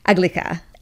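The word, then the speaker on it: aglæca: We have a lot of debate over how to pronounce this because there's no spoken text for Old English, but it's Aglika.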